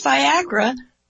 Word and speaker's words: Viagra: And if you'll notice, Viagra rhymes with Niagara.